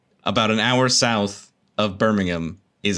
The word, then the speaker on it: Birmingham: Birmingham? – Yeah. You don't pronounce the H.